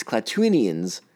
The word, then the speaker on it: Klatooinians: During the attack, Omera and Winta hide in the krill bed under a basket until the Clatuin. Jesus, I'm going to say this wrong all the time. It's Klatooinians, and I already said it wrong once.